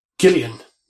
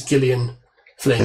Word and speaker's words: Gillian: It is Gillian. She is. Yeah. She likes to be pronounced as Gillian Flynn.